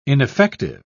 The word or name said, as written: ineffective